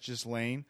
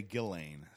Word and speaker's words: Ghislaine: I first heard her name as Ghislaine, but I've heard journalists say Ghislaine. So I'm more inclined to trust a journalist.